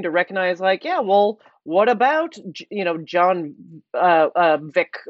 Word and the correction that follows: Wick: or whatever. Am I even saying that right? Yeah, Wick. – That's right. – John Wick. – I don't know why I was going for Vick.